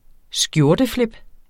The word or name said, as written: skjorteflip